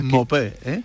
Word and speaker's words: Maupay: Se escribe Maupay, pero se pronuncia Mopé,